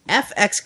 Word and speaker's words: EFX: That's how you pronounce it if you're new to this company. They're EFX is how they spell it, but EF is pronounced F and then X. So it's FX Collectibles.